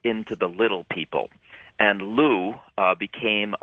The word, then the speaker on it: Lugh: Going back to a god, I mentioned a Celtic god named Lu. It's pronounced that way. It's spelled L-U-G-H.